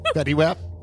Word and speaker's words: Fetty Wap: There's a Fetty Wap t-shirt, black jeans, a yellow hoodie, and sneakers. – Fetty Wap? – Yeah.